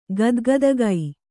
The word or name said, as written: ಗದ್ಗದುಕೆ